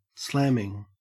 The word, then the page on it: slamming: Australian English